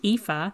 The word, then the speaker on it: Aoife: So it's pronounced Aoife, and it's spelled A-O-I-F-E, and my surname is O'Brien.